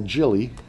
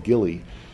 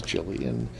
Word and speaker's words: Jilly: And there is this perky goth who I call Jilly. And it is a soft G. A lot of people pronounce it Gilly, which is sounds very odd to me. I've always thought of her as Jilly.